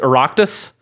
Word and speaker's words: Oireachtas: And this all stems from the Irish Senate, I'm trying to remember the correct pronunciation of the word. I'm pretty sure it's Oireachtas.